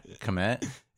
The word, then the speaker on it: Kmet: Kmet? – Yeah.